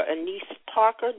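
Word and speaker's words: Annise Parker: Also, it says the beloved community has the beloved community day, and it was established by Houston Mayor Mayor Parker, Anise Parker.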